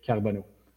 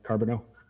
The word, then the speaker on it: Carbonneau: And on Facebook, it's my name, Dominic Carbonneau, which in English is, I don't even know how to say it, Dominic Carbonneau. See, I destroy my own name because I can't say it.